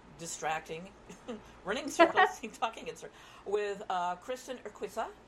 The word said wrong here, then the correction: Urquiza: Urquiza. I'm sorry. I keep thinking this Latin thing with Q-U-I. Not Spanish. Urquiza.